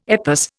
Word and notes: EPOS: Indian

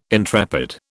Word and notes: intrepid: British English

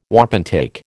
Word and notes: wapentake: American English

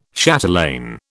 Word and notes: chatelaine: British English

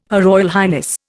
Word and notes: HRH: Indian

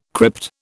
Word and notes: crypt: British English

